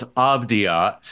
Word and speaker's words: Avdija: The names he had on this list were Kobe White, Denny Avdija, and Isaiah Hartenstein. So that leads to this question. Have you heard of any of these people? – Well, first of all, it's Avdija.